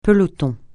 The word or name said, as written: peloton